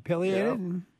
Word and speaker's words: pileated: Yeah, pileated. – Yeah, okay, that's how you say it.